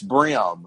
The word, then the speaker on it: bream: the fish is spelled B-R-E-A-M, but it is not bream. It's brim.